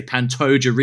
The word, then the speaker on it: Pantoja: I pronounce his name differently every time I say it. I heard John Anik say Pantoja recently, and if it's good enough for John Anik, it's certainly good enough for me.